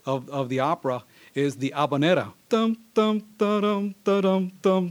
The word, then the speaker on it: habanera: Some of you may know a musical style known as the habanera, or often mispronounced as habanera, which, you know, is, I don't know where they got that mispronunciation, but it's not that, it's habanera, which means it comes from La Habana, which is Havana, right?